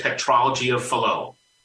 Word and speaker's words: tetralogy of Fallot: So the first thing that we were able to identify was something called tetralogy of Fallot. I'm probably mispronouncing it. – No, you're right. You got it. You nailed it.